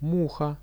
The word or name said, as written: muha